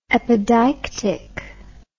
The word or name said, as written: epideictic